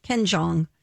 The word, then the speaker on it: Jeong: Ken Jeong.